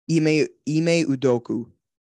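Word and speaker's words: Ime Udoka: Ime Udoku.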